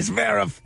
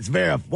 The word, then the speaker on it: Zverev: That's right. – Like that, Zverev.